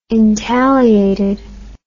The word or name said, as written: intagliated